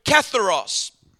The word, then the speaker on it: katharos: The Greek word pure, blessed are the pure in heart, is the word katharos. And that word, how do you say it? Katharos. You got it.